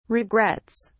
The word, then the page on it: regrets: British English